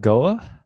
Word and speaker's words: Goa: Goa? – Yeah, that's where the Ultra Spice Race started.